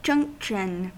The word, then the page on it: junction: American English